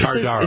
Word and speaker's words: Tardar: Tartar Sauce. – Tardar. – It's spelled Tardar. T-A-R-D-A-R.